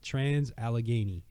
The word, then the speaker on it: Trans-Allegheny: Trans-Allegheny.